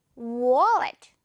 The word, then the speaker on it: wallet: 네, 이거 우리 돈을 들고 다녀야 되기 때문에 카드랑 중요한 단어인데 워, 역시 W 발음 살려서 wallet 이렇게요. wallet.